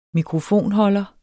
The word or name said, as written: mikrofonholder